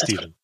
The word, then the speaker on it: Stephen: But yeah, it is Stephen. But it's like Steve, Stevie boy.